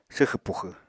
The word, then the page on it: swxẁ puxẁ: apatani